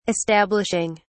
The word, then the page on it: establishing: British English